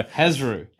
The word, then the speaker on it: Hezrou: H-E-Z-R-O-U. – There you go. – Hezru.